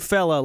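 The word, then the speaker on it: Fela: F-E-L-A-K-U-T-I. Are we even supposed to say that on the air? – Oh, it's a first name Fella, last name Cootie. Yeah, that guy. Okay. – Fella Cootie. – Who is that? – Fella Cootie.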